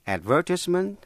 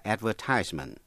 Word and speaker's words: advertisement: Có hai cách đọc, advertisement hay là advertisement, là lời đăng quảng cáo.